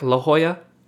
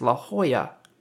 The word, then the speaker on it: La Jolla: That's called Spanish. They pronounce the J's like H's, and they pronounce a double L like a Y. So it's La Jolla.